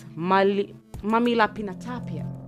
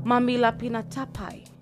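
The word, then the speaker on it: mamihlapinatapai: Yes, there's a word for that, and it's mamilapinatapia. Mamilapinatapia. No, it's mamilapinatapai. Yeah, it's mamilapinatapai.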